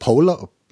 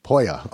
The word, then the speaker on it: pollo: What is it? Pollo? How do you say Pollo. – It? Pollo. Okay. – Pollo. El Pollo Loco.